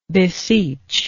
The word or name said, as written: beseechingness